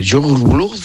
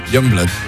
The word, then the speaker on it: Youngblood: Youngblood.